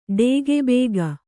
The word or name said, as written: ಡೇಗೆಬೇಗ